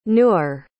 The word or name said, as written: newer